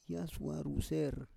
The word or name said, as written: yasuar ruser